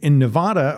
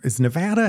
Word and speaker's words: Nevada: In Nevada, or I guess it's Nevada how it's supposed to be, how people from there pronounce it.